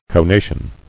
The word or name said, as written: Conative